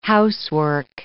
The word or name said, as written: housework